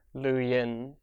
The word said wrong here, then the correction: Luo Ying: It's not actually Yin, it's Ying. Luo Ying.